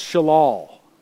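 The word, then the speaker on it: shalal (שָׁלָל): This Hebrew term for gain, chapter in verse 11, it's pronounced shalal.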